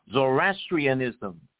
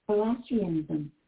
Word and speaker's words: Zoroastrianism: Zoroastrianism. – Again. – Zoroastrianism.